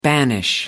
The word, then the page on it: banish: American English